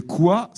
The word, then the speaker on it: coi: « Quoi », c'est un adjectif, ça s'écrit « c-o-i ».